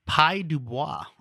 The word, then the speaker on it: Pye Dubois: The other one, the two other big notes that I have is, first of all, the lyrics for this are co-credited to Pye Dubois. – Yes. – I hope I'm pronouncing that name right. – That is correct. – P-Y-E.